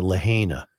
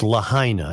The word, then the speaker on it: Lahaina: I'm also told that I've been pronouncing Lahaina wrong. – Yeah. – I've been pronouncing it Lahaina. It's Lahaina. I've gotten many emails to that end, and I will now pronounce it correctly.